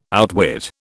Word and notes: outwit: British English

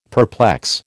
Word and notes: perplex: American English

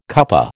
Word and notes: cuppa: American English

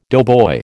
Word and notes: doughboy: American English

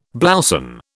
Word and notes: blouson: British English

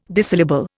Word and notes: Disyllable: Indian

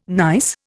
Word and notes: nice: Indian

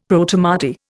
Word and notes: Protomartyr: Indian

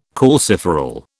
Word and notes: calciferol: British English

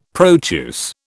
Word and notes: Proteus: British English

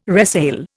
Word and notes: resale: Indian

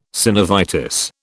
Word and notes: synovitis: British English

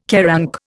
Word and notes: QARANC: Indian

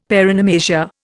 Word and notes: paronomasia: Indian

